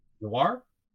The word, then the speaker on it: Noir: Like, there was Kitty Grade, there was Noir, with a really Noir? – Catchy... – Do you mean Noir? – Noir? – N-O-I-R? – Yeah, that's how I pronounce it.